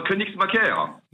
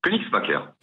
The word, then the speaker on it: Koenigsmacker: Alors, Königsmacker. – Ça s'écrit, écoutez bien, K-O-E-N-I-G-S-M-A-C-K-E-R. Et sans accent? – Königsmacker.